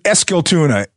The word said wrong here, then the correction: Eskilstuna: Is that wrong? Where I put the emphasis in the wrong place. What is it? Say it again. – Yeah, Eskiltuna. And Tuna is like town.